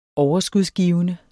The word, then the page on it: overskudsgivende: dansk